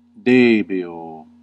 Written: devoir

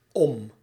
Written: over

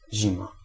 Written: hiver

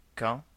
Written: as

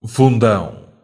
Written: abîme

